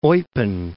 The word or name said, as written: Eupen